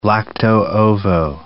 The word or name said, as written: lacto-ovo vegetarian